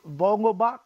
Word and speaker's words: Vogelbach: How did you this Daniel Vogelbach, how you say his name? I'm not sure how you said it. – That's right. That's right. You got it.